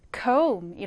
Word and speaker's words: comb: 참고로 여기 마지막에 있는 이 b 글자 있죠? 얘는 소리 나지 않는 묵음이에요. 그래서 comb 아니고 comb 이렇게 발음해 주시면 됩니다.